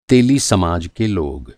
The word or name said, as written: तेली